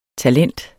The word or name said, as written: talent